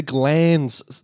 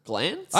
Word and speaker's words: glans: Some methods aim to increase total length, others the shaft's girth, and yet others the gland's size. Glands? – glance? – I always thought, yeah, it's that's spelled G-L-A-N-S. – Yes, I think that is right. – I always thought it was glands. – Yeah, glands, I think, are a different thing.